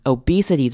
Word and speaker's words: obesity: Obesity 这个字要特别注意，它的 O 跟 E 的念法都是长母音，所以我们会念成 obesity. Obesity. 发现一个是长母音 O，一个是长母音 E.